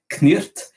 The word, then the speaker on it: Cniht: Et euh, pareil, le, le, le, on avait un titre qui était ça s'écrit C-N-I-H-T, en le prononçant en, anglais de l'époque, ça donnerait Knirt.